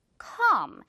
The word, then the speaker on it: calm: 그래서 calm 이게 아니라 calm. 그냥 L 없다고 생각을 하고 발음을 하는 겁니다. C, A, M만 있다고 생각하고 calm. 길게 끌어줘요.